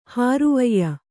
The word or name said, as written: ಹಾರುವಯ್ಯ